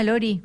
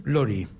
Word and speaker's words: Laurie: ¿Cómo se llama? Lori. – Lori. – Claro. – Y se escribe Laurie.